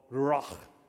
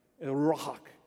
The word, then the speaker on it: ruach: There's a Hebrew word in Ezekiel 37 that's used multiple times, translated three different ways, and it's called ruach. You got to hock it up when you say it, right? Ruach.